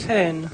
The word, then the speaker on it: Hohn: Sí, H-O-H-N. – H-O-H-N. ¿Y cómo se dice? Hen. Es alemán.